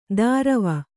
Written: ದಾರವ

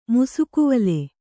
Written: ಮುಸುಕುವಲೆ

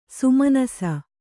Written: ಸುಮನಸ್ಸು

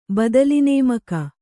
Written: ಬದಲಿನೇಮಕ

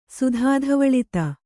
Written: ಸುಧಾಧವಳಿತ

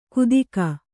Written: ಕುದಿಕ

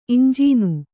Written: ಇಂಜೀನು

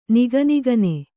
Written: ನಿಗನಿಗನೆ

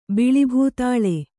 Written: ಬಿಳಿಭೂತಾಳೆ

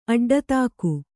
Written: ಅಡ್ಡತಾಕು

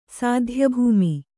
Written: ಸಾಧ್ಯಭೂಮಿ

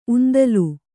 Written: ಉಂದಲು

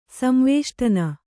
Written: ಸಂವೇಷ್ಟನ

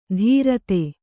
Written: ಧೀರತೆ

